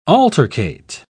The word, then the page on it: altercate: British English